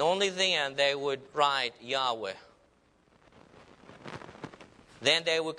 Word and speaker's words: Yahweh: It is the name of God, and just four consonants, and we pronounce it as Yahweh.